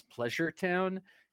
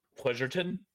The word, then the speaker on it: Pleasureton: it's pronounced Pleasureton. – Pleasureton. – Because it was probably founded by the English.